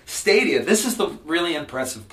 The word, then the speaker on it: Stadia: It's called Stadia. That's how you pronounce it. Thank you. S-T-A-D-I-A.